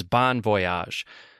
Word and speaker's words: bon voyage: First off, I want to start by saying that the accepted pronunciation in American English from the Oxford Dictionary is bon voyage, the phonetic symbol for the vowel in bon being the same for words like bottle or bomb or box.